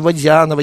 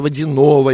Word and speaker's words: Водянова: Но когда она начала карьеру международную, она стала выходить на подиум, и ее стали ударить на «я». У нас всегда в русском языке «я» под ударением, и «ё» как. И Водянова. Она боролась, она говорила, я не Водянова, я Водянова и так далее.